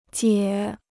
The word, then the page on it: 解: jiè